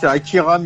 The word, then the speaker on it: Akira: Akari Miyawaki. – Miyawaki. Désolé pour la prononciation pour les japonais. Je me trompe de. Non, je crois que c'est ça, non? C'est pas. – C'est Akira Miyawaki.